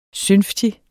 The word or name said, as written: synftig